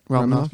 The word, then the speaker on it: Romanoff: Natasha Romanoff. That's how you say it? – Yeah, Romanoff, yeah.